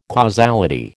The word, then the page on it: causality: American English